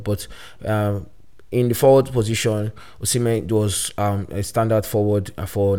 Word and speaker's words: Osimhen: Now, it's not Osimhen, it's not Osimhen. I'm giving you guys the actual Nigerian pronunciation. It's Victor Osimhen.